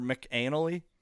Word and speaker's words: McAnally: Musgraves co-wrote all 13 tracks and co-produced the album with Luke Laird and Shane McNally. Or McAnally. There we go. I said McAnally.